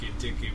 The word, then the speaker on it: Keteke: Yeah, so while I was in Ghana last week, we got to watch Keteke, which won Best Screenplay at FESPACO.